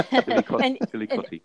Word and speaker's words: Chillicothe: And, but this project, right now that I'm doing currently is about my hometown in, Chillicothe, Illinois. – Oh, I was going to ask you how to pronounce that, because I've written it down and had no idea. – Yeah. – Chillicothe.